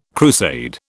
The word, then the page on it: crusade: British English